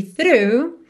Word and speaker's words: through: Through my tree, through. É só para acalmar quem se desespera quando vê essa palavra por escrito, THROUGH, a pronúncia dela é simples, through, through.